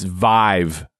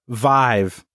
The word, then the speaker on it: Viiv: From Associated Press also, chipmaker Intel launched its Viv, and I don't even know how to pronounce this. – It's Vive. – Vive.